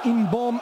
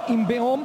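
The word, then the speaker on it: In-beom: Aí está a bola para ser movimentada por Wang Inbom, ele pronunciou o nome e cortou ali o E. Diz que é Inbom e não Inbeom, portanto se ele diz é porque é mesmo assim.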